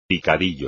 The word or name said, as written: picadillo